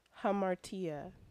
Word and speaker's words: hamartia: There's no V in it. – Mm. What was it again? – Hamartia. Hamartia. – H-A-M-A-R-T-I-A. Hamartia. Four syllables.